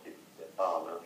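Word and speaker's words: Di Stéfano: And the number two would be Alfredo Di Stefano, or Stefano, if you pronounce it the Spanish way.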